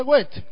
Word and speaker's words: Goethe: Y se pronuncia Goethe, así, como si leyeran en español, pero al haber una H intermedia, intermedia tras la T, tiende a separar ligeramente la E al final, es decir, Goethe.